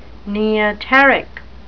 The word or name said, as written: neoteric